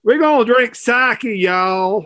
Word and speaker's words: sake: Well, I texted Brad and said, well, Bob suggested we could either have sake. Am I pronouncing that right? – Right, sake.